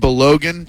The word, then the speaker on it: Balogun: Falarin Balogun.